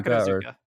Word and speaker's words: Takarazuka: Yeah, Takarazuka.